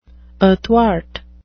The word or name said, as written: athwart